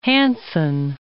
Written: Hanson

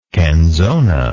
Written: canzona

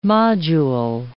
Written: module

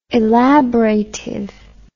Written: elaboratively

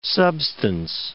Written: substance

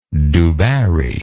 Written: du barry

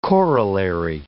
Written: corollary

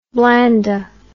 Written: Blanda

